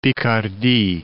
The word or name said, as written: Picardie